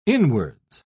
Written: inwards

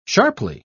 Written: sharply

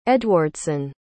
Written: edwardson

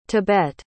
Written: tibet

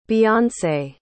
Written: Beyoncé

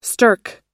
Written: stirk

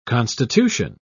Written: constitution

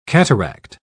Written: cataract

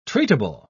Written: treatable

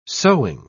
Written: sewing

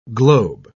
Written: globe

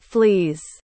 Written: fleas

